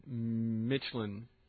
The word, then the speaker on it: Midtjylland: Our team is, how do you say it? – Michelin. – Michelin. Okay, the Danish team. Midtjylland. That's why I have trouble pronouncing it, because that's how it's spelled.